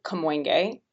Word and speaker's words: Kamoinge: So the Kamoinge Workshop, and a lot of people act- actually uh, ask how to pronounce it. And I will say that different artists actually have slightly different pronunciations of it, but I go with. That's so art world. yeah, I go with Louis Draper's pronunciation, which was Kamoinge.